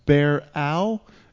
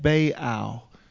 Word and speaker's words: Baal: And it's B-A-A-L, and I think that God was pronounced Baal. I've heard it pronounced Bear-Al and Bay-Al.